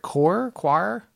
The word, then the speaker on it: coir: you could then make a rope out of it called, I don't know if I'm saying this right, coir? – Yes. – C-O-I-R.